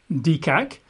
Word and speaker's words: deqaq: And the word break in pieces in the Hebrew or the Aramaic here is dekak. In English, we spell that D-E-Q-A-Q, dekak, which means break in pieces, what a surprise.